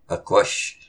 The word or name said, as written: Aqush!